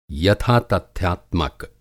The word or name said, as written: यथातथ्यात्मक